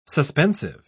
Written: suspensive

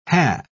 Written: hae

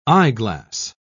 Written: eyeglass

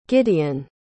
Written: gideon

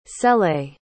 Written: cele

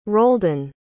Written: roldan

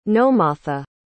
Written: nomatha

